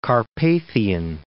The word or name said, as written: Carpathian Mountains